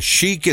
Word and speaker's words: Chic: That would be Chic with Good Times. I remember it as Chic is a band. Chick are the jeans, spelled the same way.